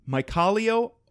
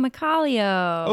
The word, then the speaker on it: Mykhailo: The farmer's name is Mykhailo Ostapyuk. – Oh, Mykhailo.